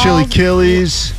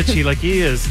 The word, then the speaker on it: chilaquiles: and it's Breakfast chili killies. – Ah, oh, the chilaquiles.